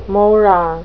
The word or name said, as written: mora